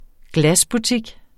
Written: glasbutik

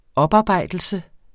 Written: oparbejdelse

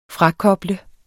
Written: frakoble

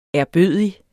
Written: ærbødig

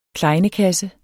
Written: KLEJNEKASSE

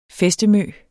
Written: fæstemø

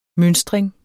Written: mønstring

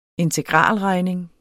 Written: integralregning

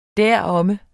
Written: deromme

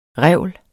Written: revl

